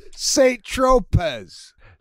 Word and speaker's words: Saint-Tropez: Saint-Tropez. – Tropez. – Oh, that's not how you say it? Saint-Tropez? Shit. – Saint-Tropez. – That's how it reads on the thing. – No.